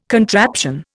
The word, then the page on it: contraption: Indian